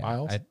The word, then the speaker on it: Biles: Is that how you say her? I keep saying her name wrong. – Yeah, Biles. I believe that's how you. I kept saying like Bales for some reason.